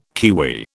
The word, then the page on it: kiwi: British English